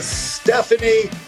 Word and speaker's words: Steffany: I'm here with Steffany Hanlen.